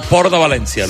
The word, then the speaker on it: Port de València: Encantado de estar en el Media Center de la Marina Nord, del Port de València.